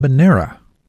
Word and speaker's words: habanero: Banera. The correct pronunciation does not make use of the Y sound.